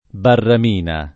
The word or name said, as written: barramina